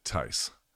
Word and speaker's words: Theis: The Tice that I had at boot camp was T-H-E-I-S, but he pronounced it Tice.